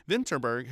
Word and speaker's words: Vinterberg: And then last year, Thomas Vinterberg, remember I pronounced his name wrong, Vinterberg, who got in last year for Another Round.